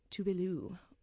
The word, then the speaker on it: Tove Lo: is Tuvalu, which, if you don't know what I'm saying, that's Tove Lo. The correct pronunciation.